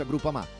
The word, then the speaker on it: Groupama: DJ Biancorosso Groupama, ah no, si pronuncia Groupama, di Paolo Felicetti.